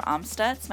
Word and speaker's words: Amstutz: You can find me on all social platforms at Sandra Amstutz. My last name is spelled A-M-S-T-U-T-Z.